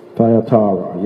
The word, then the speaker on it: Thyatira: So the churches that we did so far was Ephesus, Smyrna, Pergamum, and now we're going to do Tyatira. How you pronounce it? Yeah, the church of the T. Thyatira, yes, Thyatira, yes.